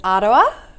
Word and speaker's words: Aroa: It has, and I had to look up how to say the name because it's New Zealand, so it's Aroa, right.